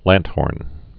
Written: lanthorn

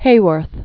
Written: Rita Hayworth

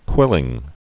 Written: quilling

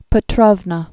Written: Elizabeth Petrovna

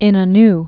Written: Ismet Inonu

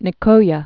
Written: Gulf of Nicoya

